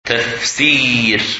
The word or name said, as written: Tafsir